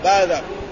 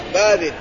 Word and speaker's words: باذق: وقد أخرج البخاري عن ابن عباس لما سأله أبو جويرية عن الباذق أو الباذق تنطق الباذق والباذق قال وهو بالباء الموحدة والذال المعجمة المفتوحة باذق وقيل المكسورة باذق وهو فارسي معرب أصله باذة.